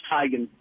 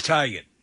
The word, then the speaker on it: Teigen: Yeah, it's a TMZ thing, and like you said, all the news media are saying her mother finally told her, it's pronounced Tegan. – It is Tegan.